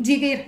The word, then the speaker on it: Djiggir: Elena eh, no sé si lo he dicho bien, ¿Cómo es? Elena. – Jigir. – Jigir, es verdad.